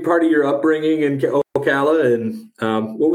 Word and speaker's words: Ocala: I like to start at the beginning with all the guests, and I want to go back to and I hope I'm saying this right, Ocala, Florida. Is that right? Oh, did I say that right? – Claps for that. I am impressed. – Okay. – Yes, you said it right.